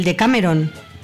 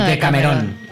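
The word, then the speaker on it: Decamerón: ¿Cómo se dice, Juanjo? ¿De Cameron o de Camerón? – ¿De Camerón? Sí, es verdad. – De Camerón. – Bueno, es italiano, tampoco. – Que lleva acento en la.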